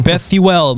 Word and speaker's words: Bethuel: It's a very nice sounding Bethuel. I don't know. People, every time I go somewhere on Twitch or on anywhere, people butcher it. Every time. No, Bethuel, my dude.